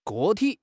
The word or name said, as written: 个体